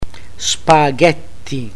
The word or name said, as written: spaghetti